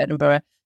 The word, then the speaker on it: Edinburgh: I will just start off actually by saying just a bit of a pet peeve for Doug and I's actually, is that it's pronounced Edinburgh, which I guess, obviously, learning how to pronounce different names, fair enough. But just to say that it's got an H at the end, so it's Edinburgh. It's not Edinburgh, so because we quite often see it without the H at the end.